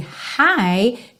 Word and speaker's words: high: Lembrando, esse high tem a mesma pronúncia do hi, hello, how are you doing? Ok? High. Então, esse GH, você não precisa se preocupar com ele.